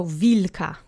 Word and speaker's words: wilka: The accent is on the penultimate syllable, so wilka.